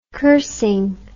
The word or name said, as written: cursing